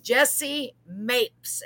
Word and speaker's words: Jessie Mapes: Please help me welcome Mrs. Jessie Mapes.